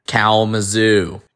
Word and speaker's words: Kalamazoo: He is going to pronounce how a Yinzer would say Kalamazoo. And we're going to add the audio here. – Kalamazoo.